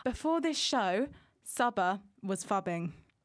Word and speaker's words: phubbing: It's called fubbing. – Fubbing? – Fubbing. – That's not a term. – Can you spell it? – It's spelt PHUBBING. Fubbing.